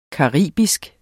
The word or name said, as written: caribisk